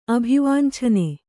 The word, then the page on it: ಅಭಿವಾಂಛನೆ: Kannada